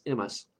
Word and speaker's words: Imbas: And he was a uh, founder and, and co-helper to get started a uh, Celtic Reconstructionist group called IMAS, I M B A S, pronounced IMAS.